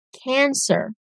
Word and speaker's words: Cancer: Câncer, os emocionados, em inglês, são descritos pela mesma palavra, sem o acento circunflexo. Então a pronúncia muda um pouquinho, ó. Cancer.